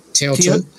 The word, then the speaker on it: Teotl: The deity, the rock art, you know, the Nahuatl word for it, since we are closer to this context, the Nahuatl word for the divine spirit is Teo, like T-E-O-T-L, Teotl. – Teotl. – Teotl, yes. Teotl, in Anglophone pronunciation, it's more like Teotl.